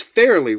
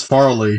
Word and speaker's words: Farley: Contestant two, we got it's Fairley, right? Or is it? – I thought it was Farley. – Okay, you're right. Whatever it was, I knew I was saying it wrong.